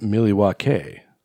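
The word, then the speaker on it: Milwaukee: Well, actually, it's pronounced Miliwake, which is Algonquin for the good land.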